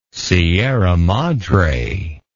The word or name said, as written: Sierra Madre